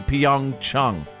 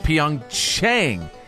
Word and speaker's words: Pyeongchang: So apparently NBC has been mispronouncing the name Pyeongchang during the Winter Olympics this year, and I guess they're doing it on purpose because it's supposed to be Pyeongchang. – Oh. – But NBC says Pyeongchang.